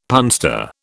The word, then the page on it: punster: British English